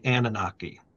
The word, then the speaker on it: Anunnaki: talk about the return of the Anunnaki. Did I pronounce that right? – Yeah, you did.